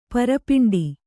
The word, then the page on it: ಪರಪಿಂಡಿ: para piṇḍi